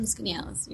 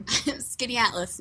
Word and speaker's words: Skaneateles: I'm from Skaneateles, New York. – You're going to need to speak up a little bit here. Say that again. – Skaneateles, New York.